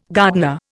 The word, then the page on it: gardener: Indian